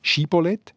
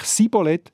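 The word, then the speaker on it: Schibboleth: Und wenn sie es richtig haben können sagen, nämlich eben Schibolett, dann sind sie durchgelassen worden. Und wenn sie es falsch gesagt haben, so wie sie eben Pfinden aussprechen, nämlich Sibolett, dann sind sie abgemetzelt worden.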